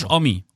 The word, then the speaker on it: Homme: Josh Omi.